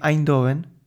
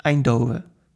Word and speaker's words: Eindhoven: Pero la forma que suelo escuchar es Eindhoven, incluso sin la N final.